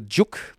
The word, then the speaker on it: Dziuk: Das Buch heißt Das Ting, also nicht Ding, sondern Ting mit T, von einem jungen deutsch-polnischen Schriftsteller namens Arthur Dziuk. Da schreibt man D-Z-I-U-K.